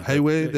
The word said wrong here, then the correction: Huawei: Huawei. – Huawei. Yeah, Huawei.